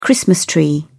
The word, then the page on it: christmas tree: British English